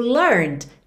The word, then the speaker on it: learned: O learned tá no passado, como a gente já viu, esse E não é pronunciado.